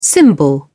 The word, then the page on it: cymbal: British English